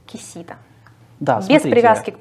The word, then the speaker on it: Кисида: Поэтому Кишида, как бы, я не считаю это совсем корректным произносить. Дело не в российской транскрипции Поливанова. Кисида немножко ближе к правде, на мой вкус.